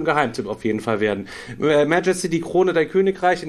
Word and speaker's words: Majesty: ganz andere Betonung. Ja, Majesty heißt das Ding.